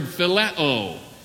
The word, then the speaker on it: phileo: Then there is a third word, which we pronounce in the Greek language by the word phileo, spelled out, it's P-H-I-L-E-O, phileo.